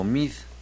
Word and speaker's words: Meade: Cual si fuera un destape adelantado forzado, Videgaray glorificó al secretario de apellido de origen irlandés que usualmente es pronunciado como Meade.